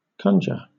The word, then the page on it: conjure: British English